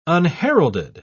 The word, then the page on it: unheralded: British English